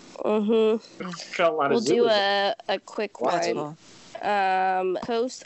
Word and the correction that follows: Cousteau: I don't even know how to pronounce this. – Cousteau. – Thank you. – From Jacques Cousteau.